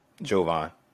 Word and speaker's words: Jovan: Yep, Jovan.